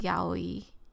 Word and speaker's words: yaoi: If it's a Japanese word, it's pronounced yaoi. – Yaoi?